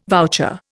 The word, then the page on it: voucher: Indian